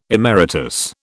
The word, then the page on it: emeritus: British English